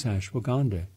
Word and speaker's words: ashwagandha: Well, what they're finding is ashwagandha. Let me spell it for you. A-S-H-W-A-G-A-N-D-H-A, ashwagandha.